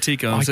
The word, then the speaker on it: Tico: I've always said Rose Tycho. – I'm pretty sure she said Tico. I'm sure she pronounced herself as Rose Tico.